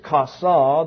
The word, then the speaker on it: chasa: The second word is kasa, the one that you see most predominantly used. It is spelled C-H-A-S-A, and it's the one I've given as an example on your handout.